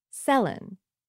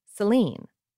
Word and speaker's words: Céline: When she was born, Madame Dion pronounced her daughter's name Céline. Kids in school pronounced it Céline. Everyone around pronounced it Céline. But when she got popular in English Canada and the States, everyone pronounced her name Céline.